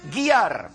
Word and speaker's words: Gere: Y resulta que se pronuncia Richard Gere. Gear.